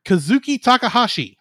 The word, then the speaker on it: Kazuki Takahashi: Kazuki Takahashi.